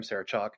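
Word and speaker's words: Sarah Chalke: Sarah Chalk was barely in this movie, and that's how you say her name, Sarah Chalk.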